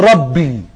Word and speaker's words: ربي: زي مثلا كلمة ربي الذي تفسيره يا معلم، فهو كاتب باليوناني ما مقابله حروف انجليزي R A B B I فتتنطق ربي، دي كلمة مش انجليزية او مش كلمة مش يونانية.